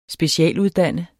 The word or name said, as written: specialuddannet